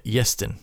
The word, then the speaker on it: Iestyn: Uh, Matthew Aubrey, Jack Walsh, and Easton Hopkins. I hope i pronounced that name right. I think it's uh, Iestyn Hopkins.